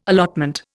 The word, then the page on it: allotment: Indian